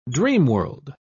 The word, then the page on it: dreamworld: British English